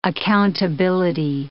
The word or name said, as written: accountability